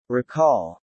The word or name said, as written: recall